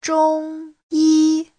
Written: zhong1yi1

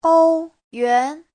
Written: ou1yuan2

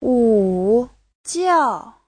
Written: wu3jiao4

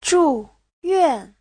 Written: zhu4yuan4

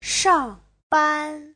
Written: shang4ban1

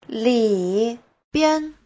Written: li3bian5

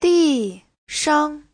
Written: di4shang5

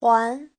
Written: huan2

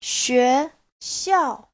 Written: xue2xiao4

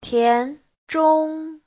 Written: tian2zhong1

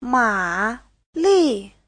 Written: ma3li4

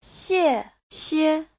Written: xie4xie5

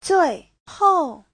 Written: zui4hou4

